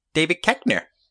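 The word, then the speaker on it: Koechner: David Kirchner? Sure. – Ben, I believe it's pronounced David Keckner.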